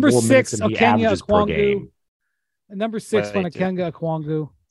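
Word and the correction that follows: Onyeka Okongwu: It's just the worst pronunciation of his name of all time. – Okay. Onyeka Okwongwu. Sorry.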